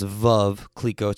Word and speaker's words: Veuve Clicquot: Actually, it's pronounced Veuve Clicquot.